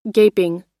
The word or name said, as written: gaping